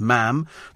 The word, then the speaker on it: ma'am: Thanks a billion, ma'am, rhyming with jam, not mom. OK, it's ma'am.